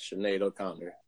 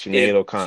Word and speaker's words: Sinead O'Connor: Sneed O'Connor. Who's that? – Sinead O'Connor. Which Sinead O'Connor.